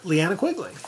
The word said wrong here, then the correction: Linnea Quigley: Linnea Quigley.